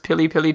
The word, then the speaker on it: Piri Piri: It's called Piri Piri chicken, or pronounced Pili Pili chicken, it means very hot.